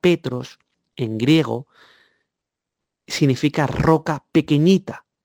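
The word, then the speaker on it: Petros (Πέτρος): Y se pronuncia Petros.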